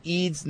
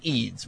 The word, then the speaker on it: Eads: Cole says he's followed the Iowa Hawkeyes for many years, and Eads' name is Eads with the long E.